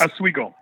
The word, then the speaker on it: Oswego: How do you pronounce the name of your city again? – Just like that. – It is. Oswego. It's the same.